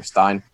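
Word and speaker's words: Steyn: it's S-T-E-Y-N, I think Stein.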